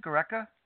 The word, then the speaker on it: Gareca: Gareca? – Yeah, that's right.